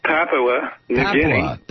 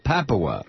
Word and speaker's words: Papua New Guinea: Blue is in, how do you pronounce this, Papua New Guinea? – Well, it's a metropolis on the south coast of Papua New Guinea. – Papua, okay.